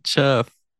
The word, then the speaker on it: Chef: I said chef. Chef. Green Chef. Uh-huh. Okay. – All right. – Yeah, I wasn't sure why it was sounding weird, but I forgot you pronounce it like SH.